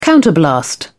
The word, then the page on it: counterblast: British English